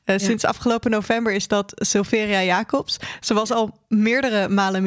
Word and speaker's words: Sylveria Jacobs: Aan Sylveria Jacobs. Zeg ik dat goed? – Sylveria Jacobs. Ja, dat zeg je goed.